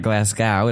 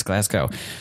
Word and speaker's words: Glasgow: It's not Glasgow, it's Glasgow. Figured that out today. Cool. So our two guests are in Edinburgh. Actually, you're from Glasgow, right? – Yeah.